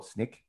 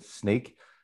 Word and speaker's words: Snyk: Some people in Europe call it Snyk, but yeah. Most people in Asia Pacific and the US, North America, pronounce it Snyk. I guess you can think of it, you know, you're sneaking around the applications or network, so it has a double meaning there.